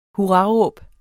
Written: hurraråb

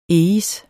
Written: eis

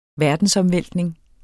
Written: verdensomvæltning

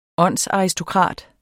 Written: åndsaristokrat

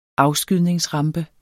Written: afskydningsrampe

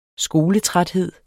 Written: skoletræthed

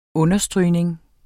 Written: understrygning

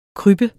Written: KRYBBEN

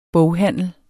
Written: boghandel

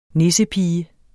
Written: nissepige

